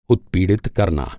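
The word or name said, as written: उत्पीड़ित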